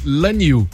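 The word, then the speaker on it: Lanyu (揽月): E o módulo de pouso foi nomeado de Lanyu, que quer dizer abraçando a Lua, uma referência a um poema escrito em 1965.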